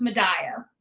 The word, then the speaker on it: Madaio: You did not. That is okay. It is a 9 times out of 10, it's wrong. So it's Madaio.